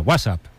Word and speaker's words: WhatsApp: No, peor aún, con acento en la primera. WhatsApp, ¿no?